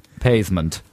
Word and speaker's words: pavement: He was so keen on being posh that he used to pronounce the word pavement, pavement. So the first syllable rhymes with bathe, pavement.